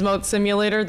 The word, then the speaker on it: Moat Simulator: Hello, my name is Moat Simulator. That's spelled M-O-A-T Simulator.